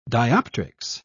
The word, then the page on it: dioptrics: British English